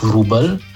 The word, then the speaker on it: Wróbel: Naprimer, poljski slavist je Henrik Wróbel, torej pišemo Vro z ostrivcem, Wróbel, izgovorimo Ujem, enako dramatik Ruževič ali pevka Gurnjak.